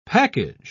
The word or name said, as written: package